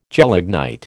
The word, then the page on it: gelignite: American English